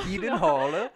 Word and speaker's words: Gyllenhaal: Gyllenhaal. – How he would have you pronounce it.